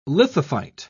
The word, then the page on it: lithophyte: British English